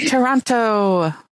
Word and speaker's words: Toronto: Were you more upset that Shanae insisted on telling us all of the parts of her body that were sweaty, or that nobody told the women that the second T in Toronto is silent? – Oh, I was going to ask you about that. We are in Toronto.